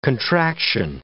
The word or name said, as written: contraction